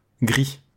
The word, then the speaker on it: Gris: C'est gris, comme ça se prononce, comme la couleur.